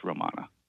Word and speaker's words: Romana: The spelling of the street is R-O-M-A-N-A. What is the correct pronunciation of this street? – It's Romana. – Yes.